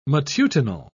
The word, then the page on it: matutinal: British English